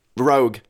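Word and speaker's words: Roeg: Rogue. Nicholas Rogue. I said his name right like 8 times, and now I said it wrong.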